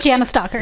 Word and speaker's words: Jana Stalker: Jana Stalker. – Yep. Jana Stalker. Sorry, I'm putting too much emphasis on the A's.